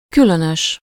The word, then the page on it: étrange: France